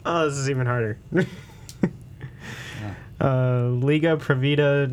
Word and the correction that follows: Liga Privada: I mean, you totally butchered the second word, but the Liga Privada.